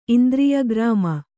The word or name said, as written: ಇಂದ್ರಿಯಗ್ರಾಮ